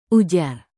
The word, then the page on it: ujar: indonesian